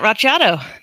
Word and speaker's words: Racciato: Hi, my name's Janet Racciato.